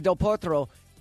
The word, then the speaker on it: Del Potro: You've got Juan Martín Del Potro, or is that how you pronounce it? Del Potro, yeah.